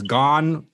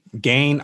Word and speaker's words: Gane: And he's actually said that you can pronounce his last name either one of those ways. Gane, Gane. I like to say Gane because it sounds fucking badass.